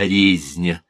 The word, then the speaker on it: резня: А мой друг говорит не резня, а резня. Я никак не могу отделаться от этого ударения на первом слоге.